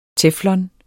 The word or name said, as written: teflon